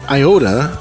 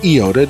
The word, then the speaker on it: iota: Now, if you know anything about languages, you'll know that an iota or an iota, depending on how you pronounce it, is is a Greek letter, and the smallest stroke in, in Hebrew is called a tittle.